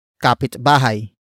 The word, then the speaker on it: kapitbahay: And that actor is kapitbahay.